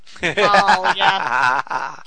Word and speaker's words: Baal: His name is seriously General Ball? – Ball, yeah. – B-A-A-L, yeah, but...